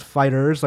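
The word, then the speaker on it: FighterZ: Kebabs mentions Fighter Z is pronounced Fighters like in the 90s.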